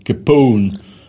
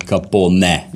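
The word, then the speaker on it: Capone: Вот, например, какой-нибудь Эл Капоун. Который у нас Капоне. – Да, а так он Капоне. Или Сильвестр Сталлоун, да, который у нас Сталлоне.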